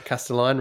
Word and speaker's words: Castelline: Castelline rhymes with design.